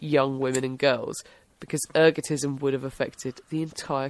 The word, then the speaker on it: ergotism: Ergotism. – Ergotism, that's it, yeah.